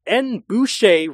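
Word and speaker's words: N'Bushe: Boucher Wright, I actually went to Wikipedia to get the pronunciation of her name, because it's spelled like N apostrophe B U S H E. So yeah, it's N Boucher.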